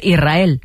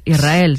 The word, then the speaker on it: Israel: Y la S con la R es difícil de pronunciar. La gente dice Israel, es verdad. – Que suene la S. Israel, sí, es difícil de pronunciar.